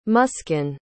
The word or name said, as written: muskan